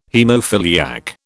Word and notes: haemophiliac: British English

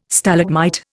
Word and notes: Stalagmite: Indian

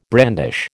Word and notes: brandish: American English

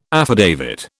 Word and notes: affidavit: British English

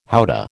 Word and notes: howdah: American English

